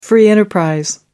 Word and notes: free-enterprise: American English

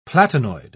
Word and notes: platinoid: British English

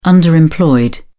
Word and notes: underemployed: British English

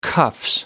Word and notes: cuffs: British English

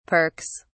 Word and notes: perks: British English

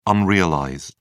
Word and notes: unrealized: British English

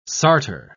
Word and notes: sartor: British English

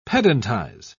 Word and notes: pedantize: British English

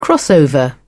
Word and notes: crossover: British English

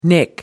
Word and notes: nick: American English

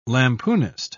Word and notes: lampoonist: British English

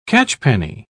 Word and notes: catchpenny: British English

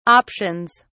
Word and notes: options: British English

